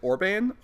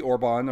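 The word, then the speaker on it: Orbán: So the prime minister, I would pronounce it Orban, O-R-B-A-N, could be Orban, or but I'd say Orban.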